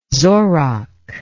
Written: zorach

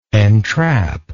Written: entrappingly

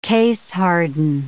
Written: case-harden

